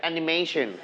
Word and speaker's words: animation: Very good.Together, animation. – Animation. – Animation. – Animation. – Very good. Okay, you stretch on the syllable may. Mation.